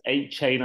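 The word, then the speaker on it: Achane: Is it, is it Achene at H A chain? Whatever it's called. I think it's H chain. I think H chain.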